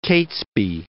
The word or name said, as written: Catesby